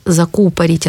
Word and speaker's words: закупорить: Перечень глаголов, где ударение падает на основу. Дозировать, закупорить, откупорить, кашлянуть, клеить... осведомиться, черпать и щелкать.